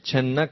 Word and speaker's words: channa: But in Kali Yuga, Lord Chaitanya, Krishna appears, but he is a hidden incarnation, Channa Kalav. Sometimes we say it like chickpeas, Channa. It's like Channa avatar. It's no long A here, Channa Kalav.